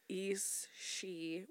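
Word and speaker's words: Aos Sí: So these spirits or fairies that were known as Eas Si, which is spelled AOS space SI, the fucking Irish, man.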